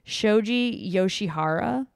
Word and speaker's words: Shoji Yoshihara: I'm going to butcher this. Shoji Yoshihara. – Sounded good.